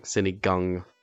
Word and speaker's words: sinigang: So I went to go try Colton's famous synagogue. Am I pronouncing it correctly? – Sinigang.